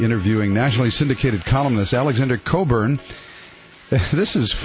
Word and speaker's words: Cockburn: It's kind of Scottish or something, but it's spelled C-O-C-K-B-U-R-N, but it's pronounced, thankfully, Coburn. I actually heard him on a show one time, and they pronounced it the way it looks like it's spelled, you know, which sounds like something would happen.